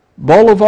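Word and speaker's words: bolívar: If you go on and look at how it compares to the dollar, even as of today, $1 is almost 250,000 bolivar, I believe is how it's pronounced, of their currency.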